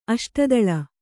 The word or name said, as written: ಅಷ್ಟದಳ